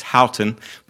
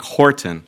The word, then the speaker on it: Houghton: Looks like it should be pronounced Houghton, but it's one of those old English names that is not pronounced the way it's spelled. It's Frank Horton.